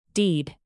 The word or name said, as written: deed